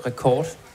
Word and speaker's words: R-Records: Vi udtaler det rekord. – Okay. – Men det er godt at få sagt en gang for alle. – En fordanskning af rekord.